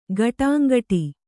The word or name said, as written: ಗಿಡುಂಗಡಿ